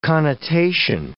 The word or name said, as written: connotation